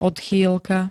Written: odchýlka

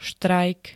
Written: štrajk

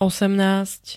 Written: osemnásť

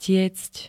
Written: tiecť

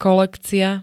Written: kolekcia

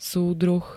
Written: súdruh

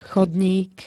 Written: chodník

